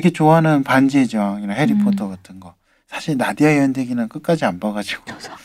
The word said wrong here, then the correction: 나니아: 나디아 아니에요? 나니아요? – 나니아가 맞을 거예요.